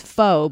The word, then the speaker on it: pho: So please tell me, if people pronounce it pho, it looks P-H-O. People always think it's pho, but it's pronounced pho.